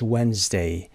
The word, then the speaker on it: Wednesday: کلمه بعدی Wednesday. دی تلفظ نمیشه دقت داشته باشید. Silent letter هست. Wednesday.